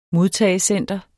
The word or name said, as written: modtagecenter